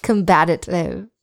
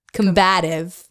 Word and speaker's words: combative: Combative. It's hard for me to not do that. I'm just adding an extra syllable. I like it. It's like a little drrr in there. Combative.